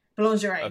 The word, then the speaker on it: boulangerie: Boulangerie? – Boulangerie, okay.